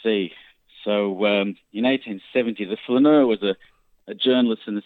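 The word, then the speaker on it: flâneur: Who was the Flaneur? And also, am I pronouncing that correctly? – Yeah, that's correct. Yeah, the Flaneur.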